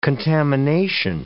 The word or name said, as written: contamination